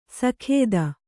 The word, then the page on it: ಸಾಕೇತ: sākēta